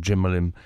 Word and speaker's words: Cemalem: Si mes cours de prononciation turque sont bons, Cemalem.